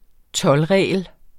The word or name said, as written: toldregel